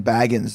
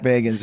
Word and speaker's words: Bagans: I think I pronounced his name wrong. I was saying Baggins. They're like, was he a hobbit? – Like Bilbo Baggins. Yeah. – Zach Bagans, yeah.